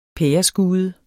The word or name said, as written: pæreskude